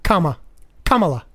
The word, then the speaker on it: Kamala: It's think of a comma. – Kamala.